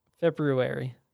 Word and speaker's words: February: I hate hearing when people mispronounce February. – February. – Yeah, everybody leaves out the R.